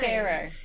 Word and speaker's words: Faro: No, that I, I think is it Faro or Pharaoh? It's it's a it's a card I think it's game. Pharaoh.